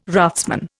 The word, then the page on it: raftsman: Indian